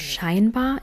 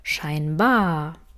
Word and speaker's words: scheinbar: Die Betonung liegt bei anscheinend und scheinbar immer auf ei. Im gesprochenen Deutsch kann die Betonung allerdings je nach Satz und dem, was wir besonders hervorheben möchten, etwas anders klingen. Deshalb hörst du manchmal auch ein deutlich hervorgehobenes bar, scheinbar.